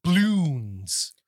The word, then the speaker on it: Bloons: B-L-O-O-N-S. – Okay. – Bloons. – Is it like apostrophe? Is it like doubloons? – No, it's like apostrophe as in balloons. – Oh. – Bloons. And gotcha. It's TD6. Bloons TD6.